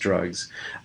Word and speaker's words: DRXUGS: It has the acronym DRXUGS pronounced drugs.